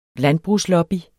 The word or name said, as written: landbrugslobby